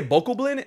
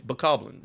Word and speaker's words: Bokoblin: Yeah, people say bokoblin, and I'm like, no, it's bokoblin.